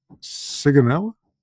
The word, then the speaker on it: Sigonella: He asked me to bring up the deployment turnover in Sigonella. – Did I pronounce Sigonella, that? Yeah.